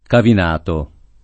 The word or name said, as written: Cavinato